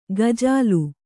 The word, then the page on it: ಗಜಲು: Kannada